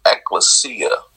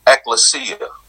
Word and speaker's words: ekklesia: So let's tackle this word, called, and the word that really comes to mind when I hear, called is, a lot of people say ecclesia is actually pronounced ecclesia, which is a Greek word, and it's actually two words put together that forms this word ekklesia.